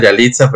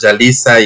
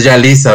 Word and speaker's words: Yalisa: suena a Yalitza, pero es Yalisa y hace ratito antes de empezar la entrevista es que te decía, oye, este es Yalisa, ¿verdad?